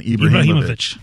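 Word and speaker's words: Ibrahimović: Zlatan Ibrahimovic.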